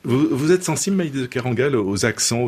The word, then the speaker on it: Maïlis de Kerangal: Et comment prononce-t-on « Maïlis de Kerangal » en finnois ? – « Maïlis de Kerangal ». – Ah oui, très simplement. Catalan, pareil. – Maïlis de Kerangal.